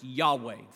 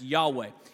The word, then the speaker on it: Yahweh: sometimes pronounced Yahweh. If you look at the original Hebrew consonants, YHWH, uh, this, some think it's pronounced Yahweh.